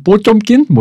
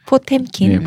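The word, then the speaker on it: 뽀쫌킨 (Потёмкин): 이게 엄밀히 말하면 전환 뽀쫌킨 뭐 이렇게 발음해야 되는데 포텐킨.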